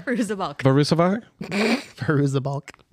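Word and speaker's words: Fairuza Balk: Farooza Balk. – She's like the most recognizable woman. Every movie she's right. in, I'm like, oh, there's... I can't fucking say her name every time. – It's Farooza Balk.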